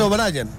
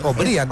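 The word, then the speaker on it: Obrian: Es que no es O'Brien. – O'Brien. – La gente cree que por como se escribe así, sí, se dice O'Brien. No, es O'Brien.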